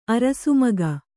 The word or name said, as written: ಅರಸುಮಿಗ